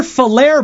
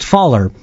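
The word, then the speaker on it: Falher: Anyways, it doesn't sound like anyone was injured, thankfully, but yes, a tornado touched down in Giroux-ville, which is near Falaire, by the way. The real ones know it's not pronounced Faller.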